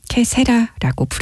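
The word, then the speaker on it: Qué será: 여기서 이용복은 이탈리아 발음인 캐사라라고 부르고 호세 펠리치아노는 스페인어인 캐세라라고 부릅니다.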